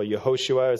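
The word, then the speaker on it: Yehoshua (יהושע): We also see that his name was Jesus, which is the Greek version of the name Joshua, or Yehoshua as it's pronounced in Hebrew, which is a very Jewish name, very common in the first century when Jesus was born.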